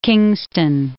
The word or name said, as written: Kingston